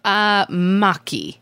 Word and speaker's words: maki: Maki.